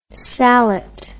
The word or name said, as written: salet